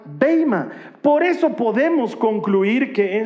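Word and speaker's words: beima (βῆμα): Ahora, lo interesante es que la palabra, la palabra griega que está utilizando aquí Pablo es una palabra que se pronuncia beima.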